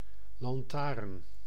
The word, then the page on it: lanterne: /lɑ̃.tɛʁn/; France (Île-de-France); Paris; [lɑ̃.tɛʁn]